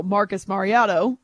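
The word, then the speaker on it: Marcus Mariota: Marcus Mariotto.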